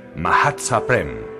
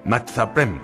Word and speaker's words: Mahatzaprem: Así que te comunico que debes pronunciar Mahatzaprem, no Matzaprem, como vienes diciendo».